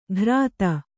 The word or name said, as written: ಘ್ರಾತ